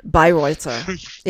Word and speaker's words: Bayreuther: Do you know how that name would actually be pronounced if you were saying it in German? – Bayreuther? – Bayreuther. Yeah, you don't pronounce the H when it's put next to a T. It's like a hard T.